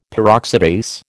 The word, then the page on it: Peroxidase: American English